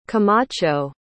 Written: camacho

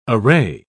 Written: array

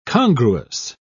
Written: congruous

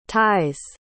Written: ties

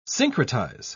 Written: syncretize

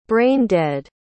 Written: brain-dead

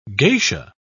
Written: geisha

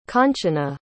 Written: Kanchana

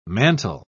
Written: mantel

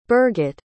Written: birgit

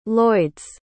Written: lloyd's

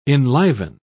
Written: enliven

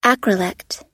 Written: acrolect

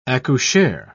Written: accoucheur

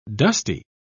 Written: dusty

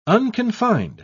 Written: unconfined

